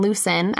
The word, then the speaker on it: Lucin: So it's called the Lucin Cutoff, and it is a 102-mile railroad line, which runs from Ogden to its namesake in Lucin. I might be saying that wrong. L-U-C-I-N. – I think that's right.